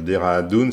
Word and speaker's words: Dehradun: Les associations, nous aurons des associations malgaches, indiennes, notamment les enfants de Dehradun, c'est comme ça qu'on prononce, Alexis, qui est sur Saint-Nazaire et qui a monté une école dans ce village en Inde.